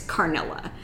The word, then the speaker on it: Karnilla: Sorry, I pronounced that uh, wrong. I keep saying Carmilla because it sounds like caramel to me, and in my head it's funny, but her real name it's Karnilla.